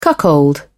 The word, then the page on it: cuckold: British English